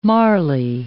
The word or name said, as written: Marly